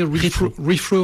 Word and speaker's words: Retro: On prononce rétro en français, rethrow en anglais, qui a un double sens dans le mot.